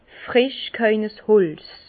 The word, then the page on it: fresch g'haue Holz: Bas Rhin; Herrlisheim